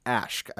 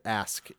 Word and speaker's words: Aşk: It's actually pronounced Ashk. I said it Ask incorrectly before, which according to Google Translate translates to love.